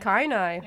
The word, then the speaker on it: Kainai: but I believe it's pronounced Canaan, but I'll confirm that after the song. But the Bullhorn Singers are from the Blood Tribe, or Canaan, which comprises... – It looks Kainai. Like Kainai.